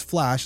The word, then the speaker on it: Phlash: He grabbed a cab and asked the driver to follow the route for Philadelphia's public transit service, also known as Flash, and that's spelled P-H-L-A-S-H, which he knew that Judy had planned on using that day.